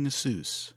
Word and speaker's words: Asus: I'm also proud of your correct pronunciation of Asus. – Yeah? – It's very well. I thought it was Asus for the longest time, and then you call their customer support, and they're like, "Thank you for calling Asus."